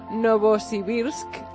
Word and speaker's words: Novosibirsk: Novosibirsk.